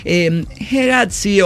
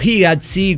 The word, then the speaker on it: Hegazi: Hegazi o Higazi, como se pronuncia, Ajá.